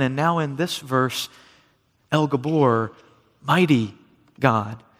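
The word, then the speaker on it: El Gibor: The one referred to as Mighty God was God himself, El Gabor, is how you say it in Hebrew, the Mighty God.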